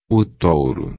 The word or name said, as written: touro